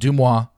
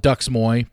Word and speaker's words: DeuxMoi: For those don't know that don't understand, it's yes, I'm very well aware it's pronounced DeuxMoi. I call it Duxmoy because I don't respect what they do, so I'm not going to call them by their name.